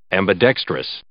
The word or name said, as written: ambidextrous